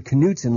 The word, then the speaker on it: Knutson: But I want you to pronounce the word Knutson like all the other words that start with an N, like new and near, okay? – Okay.